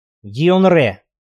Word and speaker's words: Дьенгре: Чем южнее, тем певучее произносят. В Сьерра, например, произносят слитно, с ударением на последний слог — Дьенгре.